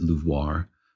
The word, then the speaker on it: LUVOIR: You know, I actually spoke with one of the scientists involved, and she pronounces it LUVOIR. I don't think she pronounces it with the LUVOIR, exaggerated Pepe Le Pew accent. But yeah, it's got a little bit of a French connotation there.